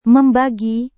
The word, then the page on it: membagi: Indonesian